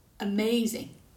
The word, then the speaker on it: amazing: Now, listen again and decide which syllable is louder, longer, has a change in pitch, and is clearly pronounced. Amazing. – The second syllable.